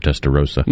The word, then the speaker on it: Testarossa: To 1986, Testarossa or Rasa? You know how to pronounce it? – I've always heard Testarossa. – Me too.